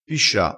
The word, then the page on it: piscia: dialettale